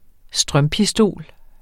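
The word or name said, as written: strømpistol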